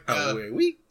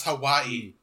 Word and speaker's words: Hawaii: It's like, Hawiwi. It's like, I was going to paradise in Hawiwi. It's pronounced Hawaii.